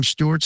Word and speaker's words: Stewart: It's my full name, Jon, J-O-N, without the H. My middle name, Alan, A-L-A-N. And my last name, Stewart, spelled like Jon Stewart, the comedian, S-T-E-W-A-R-T.